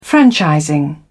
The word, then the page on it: franchising: British English